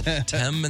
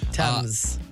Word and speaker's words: Thames: Thames and the Seine? – Mm-hmm. – Nice. – Thames.